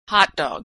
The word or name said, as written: hot dog